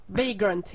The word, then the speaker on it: Vagrant: Vagrant heter det ju egentligen.